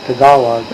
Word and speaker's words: Tagalog: And they have a language, they write it down, it's still Tagalog. It's pronounced Tagalog.